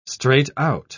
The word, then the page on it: straight out: British English